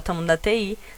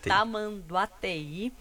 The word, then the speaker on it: Tamanduateí: um dos carros caiu no rio da Tamanduateí, falei certo? – Tamanduateí.